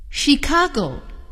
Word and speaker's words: Chicago: Chicago, he lives in Chicago. 今天挺有意思，一个字母组合 CH 有三种发音，它们是 CH、 K 和 SH。